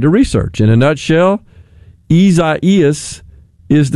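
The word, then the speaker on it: Isaías: It would appear from the way it's typically pronounced, spelled I-S-A-I-A-S. So, seems like we kind of insert an extra E in there. Between the I and the A?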